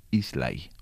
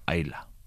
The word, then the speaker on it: Islay: Se llama Islay o Ayla, según lo queramos pronunciar.